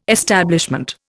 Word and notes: establishment: Indian

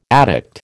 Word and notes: addict: American English

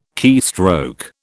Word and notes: keystroke: British English

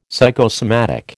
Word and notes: psychosomatic: American English

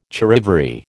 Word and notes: charivari: American English

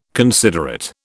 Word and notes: considerate: British English

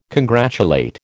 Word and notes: congratulate: American English

